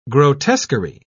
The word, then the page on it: grotesquery: British English